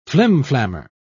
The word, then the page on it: flimflammer: British English